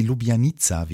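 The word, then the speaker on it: Ljubljanica: Das ist die Lubjanica, wie ich gelernt habe, so spricht man das aus.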